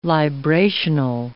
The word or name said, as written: librational